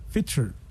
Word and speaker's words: Fitr: but that's where we have the Idul Fitr. – Fitr.